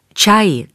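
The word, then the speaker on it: ča (茶): Čaj, káva i kakao do Londýna poprvé dorazili v témž roce, 1652. Slovo tea, čaj, se objevuje v Shakespeareovi a jeho kantonsko-makajská varianta ča s výslovností podobnou českému čaj se asi od roku 1550 náhle uchytila v Lisabonu.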